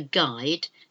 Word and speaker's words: guide: A guide. Someone to take you around. G-U-I-D-E. A guide. And something I'm sure you're familiar with is a guitar. A guitar. Again, the silent U. G-U-I-D-E. T-A-R, guitar. So those are some of the silent letters using the word, the letter U.